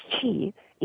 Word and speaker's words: qi: Well, the website is uh, East West QI, which is pronounced Chi, eastwestqi dot com is our website.